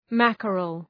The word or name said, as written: mackerel